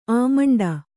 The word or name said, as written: ಅಮ್ಮಂಡ